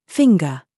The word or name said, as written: SING